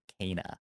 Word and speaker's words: Kena: Time for Kena. Oh, right, that happened. – Time for pronounced Kena. Pronounced Kena now. – Excuse me. – Kena. Not Kena. Kena.